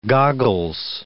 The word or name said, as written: goggles